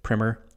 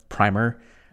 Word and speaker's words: primer: If you pronounce it as primer, spelled the same, it's an elementary textbook that serves as an introduction to a subject of study.